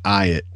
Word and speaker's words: Ayotte: But it's more melded together. It's more like Ayotte.